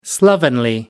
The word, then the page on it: slovenly: American English